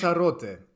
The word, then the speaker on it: Lanzarote: It's set in 1983 on the island of Lanzarote. – Yeah, is that the right way to pronounce it? How do you pronounce it in Spanish? – Lanzarote. – Lanzarote. Yeah. Okay.